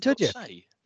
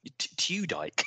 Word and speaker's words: Tudyk: In fact, I presumed his name was Alan Tudyke. so I forgot. I'm glad you pronounced it first.